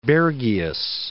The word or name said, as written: Bergius